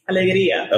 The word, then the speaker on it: Alegría: Alegría.